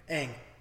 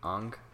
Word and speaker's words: Aang: You know, how do you say the main character's name in Avatar The Last Airbender? – I think he knows. – What'd you say? Aang. You know how they pronounce it in the movie? – Ong.